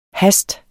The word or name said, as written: hast